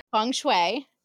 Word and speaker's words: feng shui: Feng shui. – Feng shui. I was going to say feng shui. – No. It is not pronounced the way it is written.